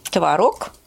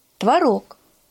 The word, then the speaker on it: творог: Творог и творог. Э, во-первых, наш язык изменяется, и он, в нем идут активные процессы. Активные он изменяется постоянно и ударение тоже может меняться. Плюс в словарях существует такое понятие, как старшая норма и младшая норма. Да, можно так, Ого. можно так. Значит, смотрите, вот правильно творог.